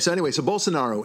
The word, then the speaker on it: Bolsonaro: Okay, but his name is Bolsonaro. – Bolsonaro. – Bolsonaro. Right. See, I'll even roll the R.